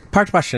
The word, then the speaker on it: Portrush: Portrush in Northern Ireland.